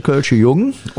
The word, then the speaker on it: Kölsche Jung: Richtig, René, du hast es falsch ausgesprochen. Es ist der echte Kölsche Jung und für alle Hörer,